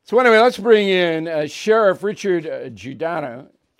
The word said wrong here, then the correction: Giardino: Giardino. Am I saying that right, Sheriff Giardino? – Giardino, thanks for having me.